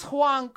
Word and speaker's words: Huang: Now, did I pronounce Huang correctly? – Yeah, you did.